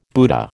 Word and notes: Buddha: American English

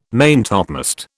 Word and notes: maintopmast: British English

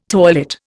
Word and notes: toilet: Indian

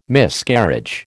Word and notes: Miscarriage: American English

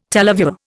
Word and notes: televiewer: Indian

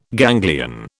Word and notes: ganglion: British English

